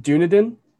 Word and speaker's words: Dunedin: I believe it is pronounced Dunedin, mm-hmm. because the name comes from the Scottish Gaelic name for Edinburgh, Dune Aiden.